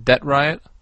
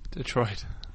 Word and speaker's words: Detroit: I'm from Debt Riot. – Debt Riot? – Detroit.